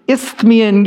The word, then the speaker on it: Isthmian: So the Isthmian Games.